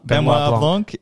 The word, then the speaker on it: Benoit Blanc: How would you pronounce it? Daniel Craig's character Benoit Blanc.